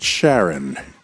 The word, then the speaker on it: Charon: David, am I pronouncing that right? Charon. – Yes.